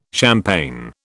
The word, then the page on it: champagne: British English